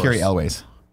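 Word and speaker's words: Cary Elwes: Carrie Hughes. Is that how you say his name? made the franchise worse. – Gary Elwes.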